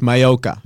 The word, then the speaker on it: Maiocca: Not good, but I've heard that version many times. It's Mayoka.